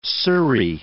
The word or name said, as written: surrey